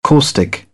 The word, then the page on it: caustic: British English; ɔː